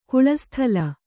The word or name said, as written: ಕುಳಸ್ಥಲ